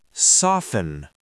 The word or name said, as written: soften